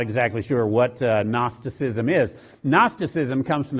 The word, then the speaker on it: Gnosticism: Both of them have a silent letter. We've got a silent K. They've got a silent G. It's not Gnosticism, just in case, you know, I don't want you to go out from here sounding like you're uneducated.